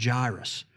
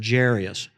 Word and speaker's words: Jairus: Well, then you need to know the story of a man named Jairus or Jairus. Both of those pronunciations are correct.